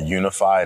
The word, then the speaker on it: YOUnify: And I work for an organization called Unify, and it's spelled Y-O-U-N-I-F-Y. And the U obviously is intentional, helping people find themselves in the unity, like the oneness.